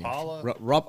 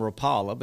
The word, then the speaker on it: Rapala: Rapala? – I called them Rapala, but he called them Rapala.